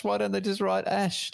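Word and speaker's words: X Æ A-12: Apparently they pronounce it Ash. It's just Ash. It's not too bad. – But it's spelt X. AEA-12. That's not Ash.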